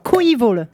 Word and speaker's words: Coeval: J'ai nommé, alors, Coeval. Ça se prononce comme ça.